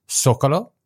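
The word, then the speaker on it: Zócalo: And I did confirm that's how it's pronounced. It's actually pronounced Zocalo.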